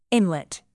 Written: inlet

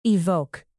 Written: evoke

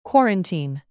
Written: quarantine

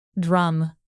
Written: drum

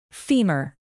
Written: femur

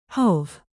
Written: hove

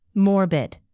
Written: morbid

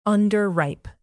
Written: underripe

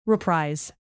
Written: reprise

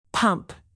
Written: pump

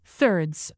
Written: thirds

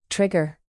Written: trigger

